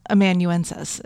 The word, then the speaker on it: amanuensis: No, you are, because I actually looked up how to do it, and it was exactly like it's spelled. Amanuensis.